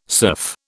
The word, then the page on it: surf: British English